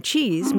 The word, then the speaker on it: chiz: And as it happens, in Urdu and Persian, the word for thing is cheese. That's spelled C-H-I-Z. And it may be that in the mid-19th century, British soldiers came back from India with this term cheese, meaning thing, and just sort of jokingly substituted it.